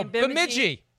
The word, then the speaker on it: Bemidji: Is Bemidji State College. – Oh, Bemidji.